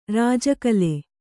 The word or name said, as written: ರಾಜಕಲೆ